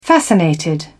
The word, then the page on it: fascinated: British English